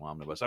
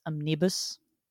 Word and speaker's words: omnibus: omnibus. I mean, I've read it before, like years and years and years ago. But right. – I believe it's pronounced omnibus. – Oh, right. Sorry, my bad.